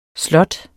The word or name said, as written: slot